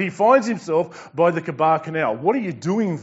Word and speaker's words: Chebar: I was among the exiles by the Chebar Canal. It's probably better pronounced Kibar Canal.